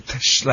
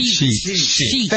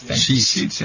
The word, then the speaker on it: sheet: I always confuse this. Shit. Like, not the shit. Okay. – Sheet. – Thank you. Sheet.